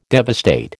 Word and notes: devastate: American English

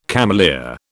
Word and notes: cameleer: British English

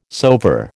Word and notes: soever: American English

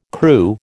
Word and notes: Kru: American English

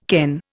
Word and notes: ken: Indian

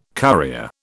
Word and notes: currier: British English

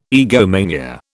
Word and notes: egomania: British English

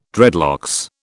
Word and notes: dreadlocks: British English

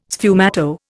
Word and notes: sfumato: Indian

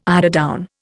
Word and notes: eiderdown: Indian

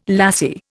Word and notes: lassie: Indian

